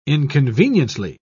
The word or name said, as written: inconveniently